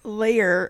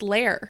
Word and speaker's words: lair: Are we pronouncing this word right? Because, or is this our accent? Because I'm hearing layer. It's layer. – The word, it's lair. – Yeah, but I can't say that. – I can't say it without thinking about it. The word is lair. So it's lair.